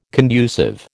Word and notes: conducive: American English

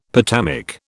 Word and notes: potamic: British English

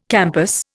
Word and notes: campus: Indian

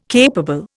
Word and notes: capable: Indian

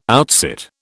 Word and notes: outsit: British English

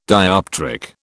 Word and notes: dioptric: British English

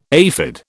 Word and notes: aphid: British English

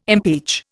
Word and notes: impeach: Indian

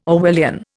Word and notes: Orwellian: Indian

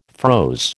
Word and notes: froze: American English

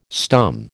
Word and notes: stum: American English

